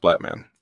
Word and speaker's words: Blattman: My last name is pronounced Blattman. Just say it like Batu Man.